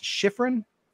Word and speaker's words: Shifrin: Brother Austin, am I saying your name right? It's Shifrin? – That's correct.